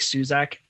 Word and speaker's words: Suszek: On Twitter, I'm at Mike Suzek, S-U-S-Z-E-K. One of those letters is silent in there. I've never actually learned which one. I might be saying my own name wrong for all I know.